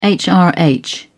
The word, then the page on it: hrh: British English